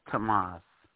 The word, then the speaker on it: Tamaz: It's Tamas. – Tamas. Like T-A-M-A-S? – Yes. T-A-M-A-Z. – Oh, T-A-M-A-Z.